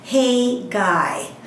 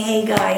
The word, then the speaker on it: Haggai: and someone said, it's like, hey, guy, and waved his hand. So decades later, I'm still saying, hey, guy, and thinking about waving your hand.